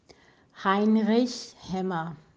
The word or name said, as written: Heinrich Hemmer